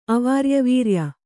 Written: ಅವಾರ್ಯವೀರ್ಯ

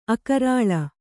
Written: ಅಕರಾಳ

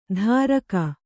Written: ಧಾರಕ

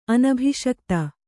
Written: ಅನಭಿಷಕ್ತ

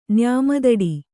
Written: ನ್ಯಾಮದಡಿ

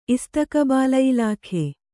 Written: ಇಸ್ತಕಬಾಲಯಿಲಾಖೆ

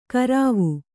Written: ಕರಾವು

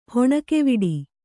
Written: ಹೆಣ್ಣುಗವಡೆ

